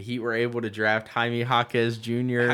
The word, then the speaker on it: Jaime Jaquez: I didn't say Jaquez. I said Jaquez. I know you don't pronounce bad. – You don't pronounce the U.